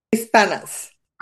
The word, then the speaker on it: Hispanas: Espouses, militaris, expenses. – Hispanas. – Ah, I was close.